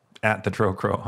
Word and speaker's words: Trocro: No, you can follow me at the Trocro on Twitter. You can follow. – Spell that. – It's T-H-E-T-R-O-C-R-O.